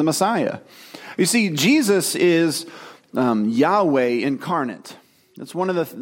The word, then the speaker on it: Yahweh: Scholars have recently discovered, and I think it's accurate, that the proper pronunciation is Yahweh.